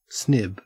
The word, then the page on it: snib: Australian English